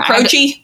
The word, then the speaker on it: Croce: Croce? – Croce, yep. – Like crotch.